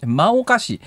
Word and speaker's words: 真岡市: と真岡市って書いて真岡市って読むんですが、はい、栃木県の真岡市で市の歴史資料保存館の敷地に立っていた二宮金次郎の銅像がなくなりました。